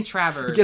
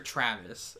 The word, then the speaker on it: Travers: I'm not saying Travers, but I keep saying Peter Travis.